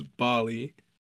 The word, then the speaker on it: Bali: It's spelled like Cali with a B. – It's Bali.